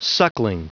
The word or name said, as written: suckling